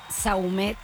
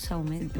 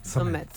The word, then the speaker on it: Saumet: Saumet. – ¿Saumet? ¿Así pues, tal cual? – Sí, nosotros los criollos decimos Saumet. A los franceses como Saumet. – Summit. Pero acá Saumet.